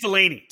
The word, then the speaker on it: Deligny: Now, did you say Deligny or? – I think it's Delaney. – Delaney. See, I see it how I spell it. But yeah, I think it's because Eric Brennan, who was there announcing on the weekend, kept saying Delaney.